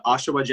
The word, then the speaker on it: Oshawa: And then how much of your work with Motion Ball and like the Oshawa Generals, am I saying that correctly? – Yep.